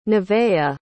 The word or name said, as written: neveah